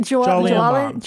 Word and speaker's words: Jolyon: Your mom. Is that how you pronounce it? – Your mom. – I forgot that you completely cannot pronounce his name. – How do you pronounce it? – Jolyon.